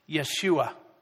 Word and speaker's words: Yeshua: It comes from the same root word as another Hebrew name that's pronounced Yeshua, which in English we would say Jesus.